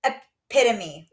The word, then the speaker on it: epitome: So I now know how to pronounce that word. Wow. – Say it then. – Epitome. – There you go.